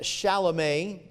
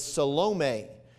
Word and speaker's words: Salome: Now, some would pronounce it Shalome. They believe that this is the roots of where we get our shalom or peace, but it doesn't say that Salome means that. The correct pronunciation with accent is Salome, but it sounds too much like salami to me, so I'm going to go, I don't want you thinking of that the whole time I'm preaching.